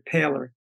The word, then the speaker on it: Poehler: He pronounces it Paler, but it's same as it's P O E H L E R.